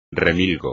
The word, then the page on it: remilgo: espagnol